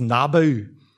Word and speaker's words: Nebo: And Nebo, sometimes pronounced as Nabu, was Marduk's son. He was the god of writing and wisdom. And this is actually a statue of Nebo that has survived to today from the 8th century BC.